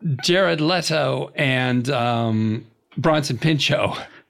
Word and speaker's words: Leto: And Jared Leto. – Leto, I believe it's pronounced.